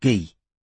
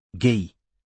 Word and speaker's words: Guy: "It's pronounced Guy," said Robin. – "What?" – "His Christian name. It's pronounced the French way. Guy."